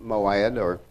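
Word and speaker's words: Moed (מועד): Even these are my feasts," which is, this word feast is pronounced Moed or Moedah.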